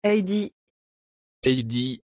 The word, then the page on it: adie: French